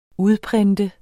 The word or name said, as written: udprint